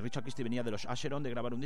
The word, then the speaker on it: Acheron: Bueno, pues ha visto cómo pronuncia en la canción Bells of Asheron, él dice Acheron, Bells of Acheron.